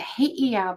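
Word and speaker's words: heiau: and a he'iau because the Hawaiians pronounce all of the vowels, their beautiful language.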